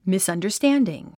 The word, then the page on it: misunderstanding: mìsʌndərstǽndiŋ; ミスアンダァスタンディン